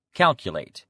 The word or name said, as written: calculate